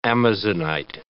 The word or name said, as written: amazonite